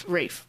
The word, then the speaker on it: Ralph: And we chose the 1992 one because Ralph. Rafe. Gosh. Guys, it's spelled Ralph.